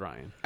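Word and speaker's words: Rian: It's spelled Rion, but it's pronounced Ryan.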